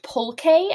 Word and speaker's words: pulque: Well, It's pronounced pulque, as it turns out. We've probably mispronounced it in the past.